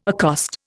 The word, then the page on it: accost: Indian